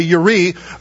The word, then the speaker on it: Jireh: Jehovah Jireh, or more appropriately pronounced in the Hebrew, Jehovah or Yahweh Yiri, which means the Lord our provider.